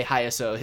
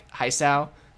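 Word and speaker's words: Hisao: Well, I say Hayaso. His name's actually like Haisao. But I pronounce it the way that Mike pronounced it in his playthrough, which is a mistake, I guess.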